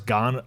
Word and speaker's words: Gane: I'm pretty sure he prefers Gone at least um, being that he's French, it only makes sense, right? There's got to be like an unpronounced syllable in there somewhere.